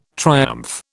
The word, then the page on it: triumph: British English